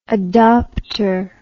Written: adopter

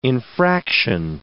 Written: infraction

